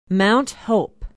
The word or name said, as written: mount hope